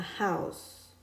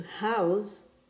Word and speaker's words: house: A house with an S sound in the end is a noun. To house with Z in the end is a verb, and it means to provide space for something or give a person or animal a place to live.